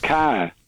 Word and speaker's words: Kerr: Josh Kerr. K-E-R-R. Sometimes it's pronounced Carr.